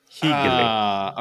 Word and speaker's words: hyggelig: H-Y-G-G-E-L-I-G. Hegele.